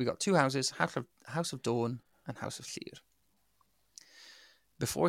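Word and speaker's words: Dôn: Without it, without the circumflex above it, it would be Don, as in Don Cheadle. But because it's got the circumflex, it's pronounced Dawn, as in, you know, from dusk till dawn.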